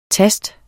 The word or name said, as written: tast